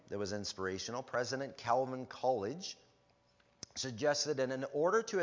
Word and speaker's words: Coolidge: I think I said it right. Coolidge. There we go.